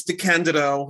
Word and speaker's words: DeCandido: Today I'm talking to Keith DeCandido. Did I say that right? – You did. Congratulations, you passed the first test.